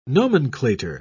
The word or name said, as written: nomenclator